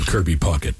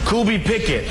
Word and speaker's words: Kirby Puckett: Well, still, the greatest ever was the top 10 mispronunciations of Kirby Puckett. – Category tonight, top 10 ways to mispronounce the name Kirby Puckett. Here we go. Number 10, Kirby Pickett.